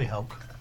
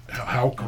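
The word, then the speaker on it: Howke: But probably Houke. – Houke, okay.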